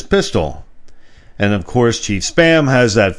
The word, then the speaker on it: Spa'am: Now, it's spelled like ma'am, SPA'AM, but the chief pig's name is Spam.